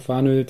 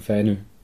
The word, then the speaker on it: Fanø: Äh, also, die Deutschen sagen Fanö, die Dänen sagen Fänö.